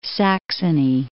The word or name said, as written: Saxony